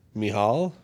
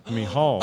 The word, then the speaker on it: Michal: So, the name looks like it was Hebrew originally, and it looks like it's pronounced M-I, like you would say, me. The C-H is the same sound that Hanukkah is, the C-H of Hanukkah. So, ha, and then A-L. – Mihal? – Mihal, I was the closest!